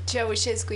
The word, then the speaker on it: Ceaușescu: I follow how do you say it? Ceaușescu? – Ceaușescu is his name.